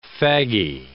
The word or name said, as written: faggy